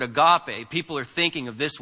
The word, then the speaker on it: agape: In the Bible, you have this word agape, which many people mispronounce as agape, but that's fine. It's agape. The accent's on the end.